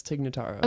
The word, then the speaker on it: Tig Notaro: You know, I saw this documentary about Tig Notaro. Am I saying that right? – Oh. – Yeah, I think that's Tig Notaro.